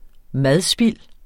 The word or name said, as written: madspild